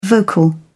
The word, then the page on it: vocal: British English